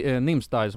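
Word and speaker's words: Nimsdai: Men så här, jag har faktiskt följt Nimstai som han heter. Som han uttalar så.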